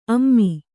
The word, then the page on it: ಅಮೆ: ame; kannada